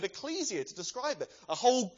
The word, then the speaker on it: ecclesia: Actually, the English word church that we have in our Bibles comes from a Greek word that you pronounce ecclesia, which simply means an assembly, a gathering together of people.